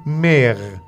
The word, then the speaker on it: mère: Madre, en français, se dit mère. M-E, accent grave, R-E. Mère. M-E, accent grave, R-E. Se prononcia mère.